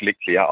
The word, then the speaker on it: Leclerc: Und an das habe ich mich dann gehalten und alle haben dann gesagt, ja, das gibt's nicht, da muss das K hinten dran. Aber egal, er selbst spricht sich Leclerc aus, ja.